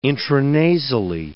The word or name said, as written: intranasally